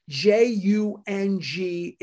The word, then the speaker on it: Jung: J U N G is pronounced Jung.